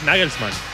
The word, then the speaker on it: Nagelsmann: Es Nagelsmann.